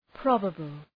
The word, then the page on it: probable: 'prɒbəbəl